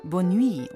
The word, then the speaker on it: Bonne nuit: Das spricht man Bonne nuit und das ist französisch und heißt auch wieder Gute Nacht.